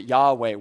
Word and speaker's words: Yahweh: In Christian circles, we tend to pronounce it Yahweh, Y H W H.